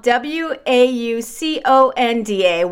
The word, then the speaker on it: Wauconda: It's spelled W A U C O N D A, Wauconda, Illinois. But um, like I said, they're receiving a lot of attention because it's pronounced very similar to Wakanda.